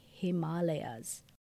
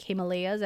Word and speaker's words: Himalaya: Uh, sidebar I know I've uh, pronounced this different before, like Himalayas, as most Americans do. But the correct Sanskrit name or pronunciation for this mountain range is Himalaya. Himalaya. I know it's easy to say Himalaya, but if you want to say it the right way, it's Himalay. Himalay.